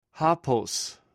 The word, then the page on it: hopws: greek